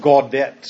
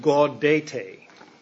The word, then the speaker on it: Gaudete: We want to call it Gaudet Sunday, but I believe it's Latin, and I'm not a Latin scholar. I never took Latin. I believe it's pronounced Gaudete. But anyway, Gaudete Sunday.